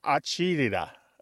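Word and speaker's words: agita: It's pronounced something like acidita. And that became corrupted in the mouths of people who were maybe second or third generation Italian Americans and not, you know, fresh from Italy or fresh from the parts of Italy where they use this word.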